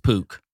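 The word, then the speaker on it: Puk: Not the poop stone. – No. – Pook. It's P-U-K, but yeah. It's pronounced Pook.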